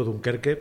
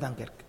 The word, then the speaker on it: Dunkerque: Yo no tengo muy claro que cuando Héctor Rodas va a ver a tu equipo, yo lo pronuncio mal, yo digo Dunkerque, pero no es Dunkerque, tú lo pronuncias bien. – Es Dunkerque.